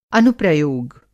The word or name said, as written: अनुप्रयोग